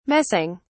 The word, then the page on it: messing: British English